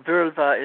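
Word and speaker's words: Völva: And the idea of the shaman's role is, would be a Virva is the pronunciation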